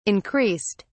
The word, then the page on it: increased: British English